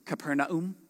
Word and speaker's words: Capernaum: When Jesus had entered Capernaum, or if you want to know the real way to pronounce it, it's Capernaum. A centurion came to him asking for help.